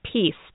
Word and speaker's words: PEAC: Actually, we pronounce that peace, don't we? – Yep, we sure do.